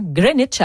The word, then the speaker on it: Greenwich: Et on ne prononce pas le W. Donc, ça fait un Greenwich.